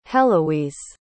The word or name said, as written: heloise